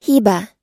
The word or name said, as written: hiba